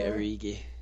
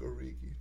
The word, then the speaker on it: Origi: Keep alternating soft G's and hard G's. – I say Origi. – Divock Origi. Divock Origi. – Divock Origi. – Yeah, okay, I'll go hard G.